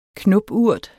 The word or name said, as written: knopurt